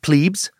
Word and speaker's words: plebs: Terms key to beginning uh, to understand the Roman Republic patricians, uh, plebeians, or plebs, sometimes pronounced plebes, uh, praetors, sometimes pronounced praetors, consuls, and censors.